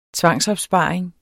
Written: tvangsopsparing

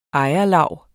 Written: ejerlav

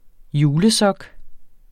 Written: julesok

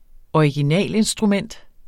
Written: originalinstrument

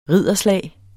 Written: ridderslag

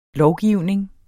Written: lovgivning

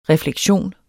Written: refleksion

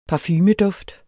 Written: parfumeduft

